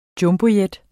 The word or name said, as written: Jumbojet